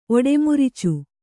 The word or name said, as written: ಒಡೆಮುರುಚು